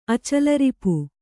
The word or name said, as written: ಅಚಲರಿಪು